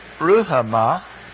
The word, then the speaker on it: Ruhamah: Then Ruhamah, as we would pronounce it in English, is from the verb raham, really raham, and that means mercy.